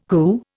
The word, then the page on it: coo: Indian